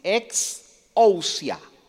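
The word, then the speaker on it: ex ousia (ἐξουσία): Entonces, ahí la palabra no es dunamis, Ahí la palabra es eusoxia. Ex, así como, e, como exit, ex ousia.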